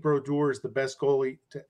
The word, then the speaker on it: Brodeur: So to me, the number one, number 30, has to be Martin Brodeur. Did I pronounce that well? – Yes, I liked it.